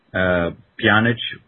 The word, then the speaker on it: Pjanic: I think, Pjanic, which is the pronunciation I'm going with, which is correct.